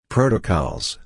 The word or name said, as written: protocols